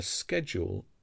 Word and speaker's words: schedule: A schedule is a list of planned activities with the times or dates when they should happen. This word has several complicated sounds and two possible pronunciations.